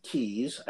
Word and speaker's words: Kyes: Now, next we are going to do Nancy Keyes. I think I'm pronouncing her name right. – K-Y-E-S. Yeah.